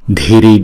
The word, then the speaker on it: Dhiri: Dhiri dhiri. Chữ D với H ghép lại chúng ta đọc là Đ, Đ. Dhiri dhiri, dhiri dhiri.